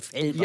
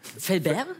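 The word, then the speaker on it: Fellbach: Es gibt aber so viele Leute sprechen das falsch aus, weil wir auf der anderen Rheinseite sind, die denken, das ist schon so weit an Frankreich, dass man das. – Fellbert? – Ja, ja. So viele Leute wollen das immer so aussprechen, deswegen du hast das genau richtig ausgesprochen.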